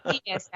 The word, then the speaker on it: Aeneas: Aeneas, actually, Aeneas.